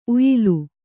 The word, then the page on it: ಉಯಿಲು: kannada